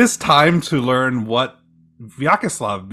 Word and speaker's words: Vyacheslav: It's Vyacheslav. – Well, I didn't say that. I said Vyacheslav. – You can pronounce it either way.